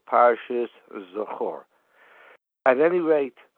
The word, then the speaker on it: Zachor: Most people say it's Parshas Zachor. I just want to make a correction in the way it's been pronounced. It's really, the pronunciation is Zachor.